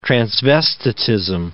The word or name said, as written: transvestitism